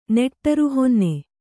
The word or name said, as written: ನೆಟ್ಟರುಹೊನ್ನೆ